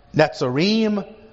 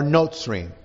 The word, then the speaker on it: Netzarim: which in Hebrew is pronounced Netzarim or Notzrim, depending on how you want to do your accent. Netzarim or Notzrim.